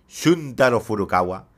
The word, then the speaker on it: Shuntaro Furukawa: Shuntaro Furukawa.